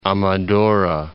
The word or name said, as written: Amadora